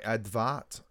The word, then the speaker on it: Edvard: So, before World War One, the pronunciation of Edward was more like Edvard.